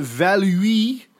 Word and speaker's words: Valluy: And General Jean-Étienne Valui, and I'd like to thank all of our French listeners for helping me out on Facebook last night to tell me how to pronounce V-A-L-L-U-Y, Valui, Valui.